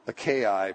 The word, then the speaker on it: Achaia: It's not the store, it's the region. I don't even think I said that right, actually. Achaia probably is the proper pronunciation.